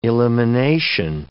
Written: elimination

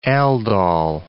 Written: aldol

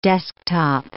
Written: desktop

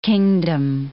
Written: kingdom

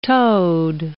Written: toad

